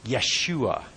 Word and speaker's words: Yeshua: Because the name that we pronounce as Jesus, the children of Israel would have pronounced as Yeshua.